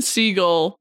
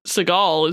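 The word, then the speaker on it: Segel: Also, fun fact, this is when I learned that Jason Seagull is pronounced Seagull, not Seagull.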